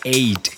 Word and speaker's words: Ait: Les estoy hablando de la señorita Eight, se escribe A-I-T,